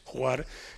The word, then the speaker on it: Hvar: Es que si hay otra isla emblemática es Juar, que se escribe H-V-A-R.